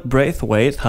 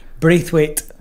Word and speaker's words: Braithwaite: Mogwai mit Party in the Dark, die Band, beziehungsweise ein Mitglied ist immer noch bei mir im Studio, nämlich Stuart Braithwaite. Hi. Hey. – That's a difficult name for me to pronounce. – Really? – Bra can you say it? – Braithwaite.